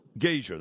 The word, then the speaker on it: Gasior: Now, how do you say your last name? – Gasher, that's Gasher. What I thought.